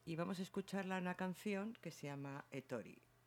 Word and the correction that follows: Erori: Bueno, creo que se pronuncia así, porque después pone Erori. ¿Cómo? – Es Ízaro y la canción es Erori.